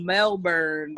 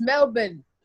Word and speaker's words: Melbourne: That nigga, she put a spotlight on the bullshit he's been spewing around Melbourne, Australia. Oh. – You're not saying it right. It's Melbourne.